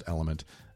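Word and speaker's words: LMNT: So I'm here to tell you about Element. It's L-M-N-T. It's pronounced Element.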